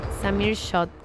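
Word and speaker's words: Samir Xaud: Samir Shot, creo que debe de ser la pronunciación, porque se escribe X-A-U-D.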